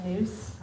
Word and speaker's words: nous: And I like the term, the mind is the Greek word nous. I mean, so that's how you say it, but it's N-O-U-S.